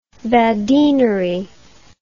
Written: badinerie

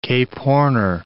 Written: Cape Horner

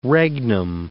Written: regnum

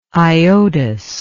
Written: iodous

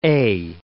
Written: eh

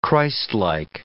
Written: Christlike

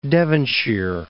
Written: Devonshire

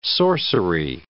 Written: sorcery

